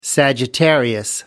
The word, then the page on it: sagittarius: American English